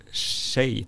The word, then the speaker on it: Hsieh: Jag tror jag har sett den, jag har inte läst den skriven av en kille som heter eh, Tony Shei, tror jag det uttalas. Det är ett asiatiskt namn, det stavas H-S-I-E-H.